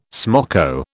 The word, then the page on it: smoko: British English